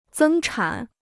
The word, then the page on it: 增产: zēng chǎn